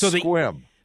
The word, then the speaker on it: Sequim: It's squim. – So the E is just not pronounced at all. – Yeah, it's squim. – See, and I was going with Q-U sounding like a K.